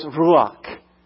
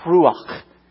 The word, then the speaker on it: ruach: When you say ruach in Hebrew, as you say the word is, the pronunciation of the word is what it means.